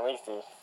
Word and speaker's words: Lethe: You're showing me of, I'm currently watching this, game on YouTube, it's called, I believe it's pronounced, Lethe. – Yeah, Lethe.